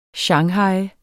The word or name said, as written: shanghaje